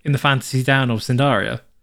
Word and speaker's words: Cnidaria: After that, um, it's a, it's a genus of a small freshwater organism of the Phylum Sindaria, a class of hydrozoa? Phylum Cnidaria. Is it the C silent? The C is silent.